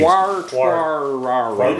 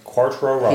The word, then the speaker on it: Quartararo: Quartararo. You know why you can't say it right? – Quartararo. – He's French.